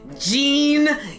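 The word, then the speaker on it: Jeen-yuhs: So yeah, no, Hellbound looked pretty good, but what everybody is probably talking about at this point is the Kanye West documentary, Gene. Yes! And say it like that because it is spelled the most phonetic way possible. – J-E-E-N hyphen Y-U-S.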